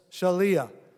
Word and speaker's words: shaliah: It's spelled S-H-A-L-I-A-H. I probably would have put Shaliah, and then you could have just said Shaliah.